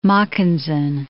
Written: Mackensen

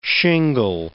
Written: shingle

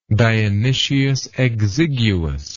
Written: dionysius exiguus

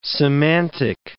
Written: semantic

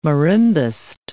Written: marimbist

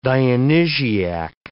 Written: Dionysiac

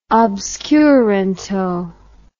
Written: obscuranto

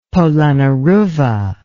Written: Polonnaruwa

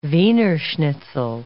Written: Wiener schnitzel

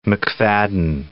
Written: McFadden

